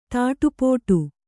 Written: ತಾಟಿಪೋಟಿ

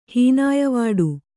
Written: ಹೀನಾಯವಾಡು

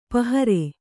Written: ಪಾಹರೆ

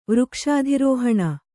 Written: ವೃಕ್ಷಾಧಿರೋಹಣ

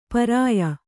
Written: ಪರ್ಯ

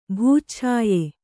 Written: ಭೂಚ್ಛಾಯೆ

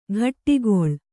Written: ಘಟ್ಟಿಗೊಳ್

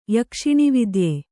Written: ಯಕ್ಷಿಣಿವಿದ್ಯ

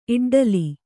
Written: ಇಡ್ಡಲಿ

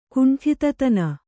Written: ಕುಂಠಿತತನ

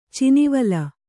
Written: ಚಿನಿವಾಲ್

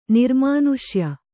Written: ನಿರ್ಮಾನುಷ್ಯ

